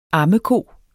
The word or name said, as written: ammeko